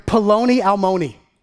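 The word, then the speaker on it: paloni almoni (פלוני אלמוני): It's the word "paloni almoni." Paloni almoni. I like that word. We should, you know, bring it back. Paloni almoni. Rhymes with macaroni and bologna, right?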